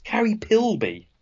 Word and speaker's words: Carrie Pilby: Carrie Philby. Well, it's Carrie Pilby. – Oh, yes, it is. There's no H.